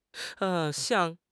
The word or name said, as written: 巷